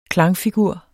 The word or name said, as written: klangfigur